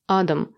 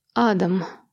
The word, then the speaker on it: Адам: А поляки произносят это имя с ударением на первом слоге. Адам». – «Значит, Адам».